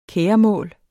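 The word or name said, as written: KÆREMÅLS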